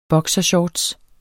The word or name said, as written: boksershorts